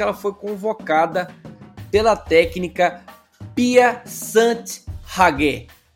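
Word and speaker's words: Pia Sundhage: Vou até repetir, ó. Pia Sant Hage. Nossa Pia Sant Hage, ó. – Ó, a é verdade, agora eu falei errado. Pia Sant Hage.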